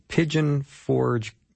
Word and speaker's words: Pigeon Forge: He's got a tremendous ministry in Pigeon Forge, Tennessee. I think I said that right. Is that right, John? Welcome. – You sure did.